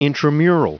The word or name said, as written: intramural